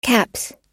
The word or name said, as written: caps